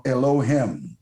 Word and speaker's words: Elohim: Number one, it's pronounced in the original Elohim.